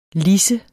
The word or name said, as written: lidse